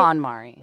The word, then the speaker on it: KonMari: Marie Kondo is a Japanese organizational consultant whose method is known as KonMari. Am I pronouncing that right? – KonMari.